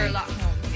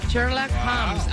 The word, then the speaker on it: Sherlock Holmes: Sherlock Holmes, yeah. – Sherlock Holmes.